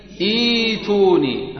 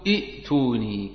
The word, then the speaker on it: ائتوني: نبتدي إيتوني ها انطقوا هكذا تمام الله يفتح عليكم وإياكم أن تقولوا ائتوني